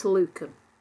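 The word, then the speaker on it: lucem: Now, I have a friend who's a retired Latin teacher who says it's got a hard C, it's Luchem.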